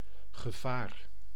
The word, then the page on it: hazard: [ˈhæ.zəd]; /ˈhazəd/